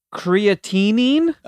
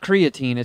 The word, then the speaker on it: creatinine: There's creatinine. – I love creatine. That's good for your muscles. – It's got an extra syllable. It's not creatine.